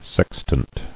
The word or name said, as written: Sextant